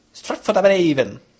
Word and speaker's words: Stratford-upon-Avon: Und es war ziemlich kompliziert, am Bahnhof nach der Stadt zu fragen, denn während ein Deutscher vielleicht sagen würde, Stratford-upon-Avon, hat die Dame am Bahnsteig gesagt, Stratford-upon-Avon. Also um das A noch ausgesprochen, das O einfach irgendwie verschluckt, das RD, FROD in einem Satz.